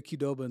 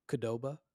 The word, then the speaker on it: Qdoba: But like old friend from the core, called Qdoba. – Oh yeah. – Like they wouldn't even pronounce the Q, and it bothered me so much.